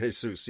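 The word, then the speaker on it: Jesús: No, in Spanish, it's Jesus. – Oh, it's H in Jesus, yeah. So, yeah. – Jesus, yeah. – But even in Celtic, it's a Y. Jesus, okay?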